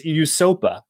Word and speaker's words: USOPA: which is pronounced USOPA and stands for the United States Olympians and Paralympians Association.